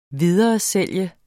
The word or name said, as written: videresælge